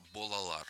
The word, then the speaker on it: Балалар: А группа называется «Балалар», если я правильно, да, произнес. – Вот, да, я тоже не эксперт, но вроде как будто вот читается как «Балалар».